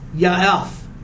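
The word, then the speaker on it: yaeph: In the verse 29, it's the word ya'ef. Ya'ef. Y-A-E-P-H. Y-A-E-P-H.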